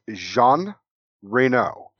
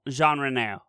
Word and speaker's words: Jean Reno: But even, again, and say his name again, because I'm going to fuck it up. – So it's Jean Reno. – Jean Reno.